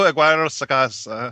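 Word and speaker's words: Aguirre-Sacasa: Roberto Aguirre Sacasa.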